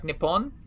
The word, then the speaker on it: Nippon: There's multiple pronunciations depending upon region for the kanji that form the name of the country, but generally it's pronounced something like Nippon.